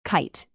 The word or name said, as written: kite